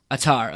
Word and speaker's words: Attar: Attar.